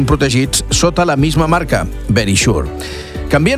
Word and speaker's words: Verisure: O Verisure, l'he dit tal qual, Verisure. Seria la pronunciació correcta. Verisure està com escrit, però és Verisure.